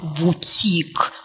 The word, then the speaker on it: бутик: Да, иногда заимствования используются для того, чтобы выглядеть таким специалистом с зарубежной подготовкой, назвать свой маленький магазинчик не лавочка, а бутик с ударением на «и». Потому что если сказать «бутик», то сразу будет понятно, что никакой зарубежной подготовки там рядом не стояло.